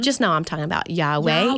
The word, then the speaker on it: Yahweh: So the first one we did was on Yahweh, the Hebrew name of God, which sometimes you might hear this name pronounced Jehovah. It's technically should be pronounced more Yahweh is closer.